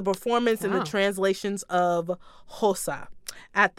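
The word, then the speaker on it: Xhosa: It's X-H-O-S-A. – Hosa? – Hosa.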